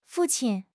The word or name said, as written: fu4 qin1